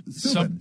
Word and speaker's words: Subban: And he, PK Sub, it's, I always say his name wrong. – Subban.